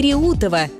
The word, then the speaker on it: Реутов: В новостях и разговорной речи часто возникает путаница с ударением в названии подмосковного города Реутов и станции Реутова. И ошибка здесь встречается регулярно. Правильно, город Реутов с ударением на «е» и железнодорожная станция Реутова с ударением на «у».